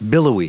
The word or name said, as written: billowy